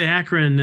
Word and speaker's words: Akron: Hopefully I didn't butcher, is it Akron? Akron? – It's Akron.